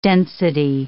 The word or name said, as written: density